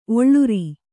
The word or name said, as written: ಒಳ್ಳೂರ್